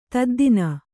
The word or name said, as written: ತದ್ದಿನ